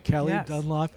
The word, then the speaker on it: Calee Dunlap: Calee Dunlap.